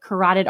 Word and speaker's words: carotid: And they were actually able to repair her carotid artery. Did I say that right, medical professional? – Yes, ma'am.